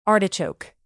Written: artichoke

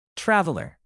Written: traveler

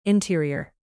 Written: interior

